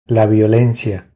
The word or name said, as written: La violencia